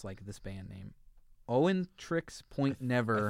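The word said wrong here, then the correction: Oneohtrix Point Never: I think it's Oneo Trix Point Never. – Oneo Trix Point Never, Garden of Delete.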